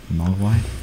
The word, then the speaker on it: Mogwai: Mogwai.